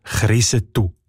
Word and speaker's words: Kriesental: Und wie der Ueli Sagesser schon herausgefunden hat, kommt der Name vor Ort als Krisetu ausgesprochen.